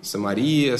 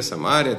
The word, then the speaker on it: Самария: Самария. Самария, Самария, там, по-разному ставят ударение.